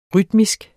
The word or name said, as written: rytmisk